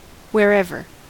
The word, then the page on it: wherever: American English